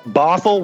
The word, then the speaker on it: Bothell: I am just outside of Seattle, a little town called Bothell, Washington. – How do you say Bothell or with an F or a T-H or what? – T-H. – T-H.